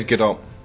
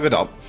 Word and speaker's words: Gadot: But you know what, actually, if I imagine her speaking, she doesn't, there's no silent T's. So yeah, I'm going to say Gadot. – Yeah, that has hard stuff. – There is a pronounced T, apparently.